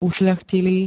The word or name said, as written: ušľachtilý